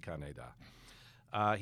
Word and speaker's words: Kaneda: I mean, the weird thing about I mean, it's not weird. Just the natural thing about Japanese is there's very little stress on a syllable unless they say it specifically. – Right. – So if there's no character over any of the vowels, it's kaneda.